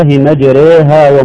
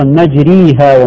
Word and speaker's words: مجريها: النطق الصحيح بسم الله مجريها ومرساها. بعضهم إما يفتح مجراها وبعضهم إما يكسر كسرا خالصا مجريها ومرساها. – وهذا يعني خطأ. – خطأ طبعا. وهي وسط الإمالة بين الاثنين بين الفتح وبين الكسر الخالص.